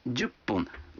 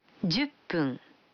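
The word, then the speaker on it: дюпун (十分): А 10 минут – дюпун. Вы заметили, что когда фун добавляется к 10, дю, то произносится пун. – Послушайте и повторите каждую фразу. 5 минут. – Гофун. – 10 минут. – Дюппун.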